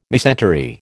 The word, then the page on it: mesentery: American English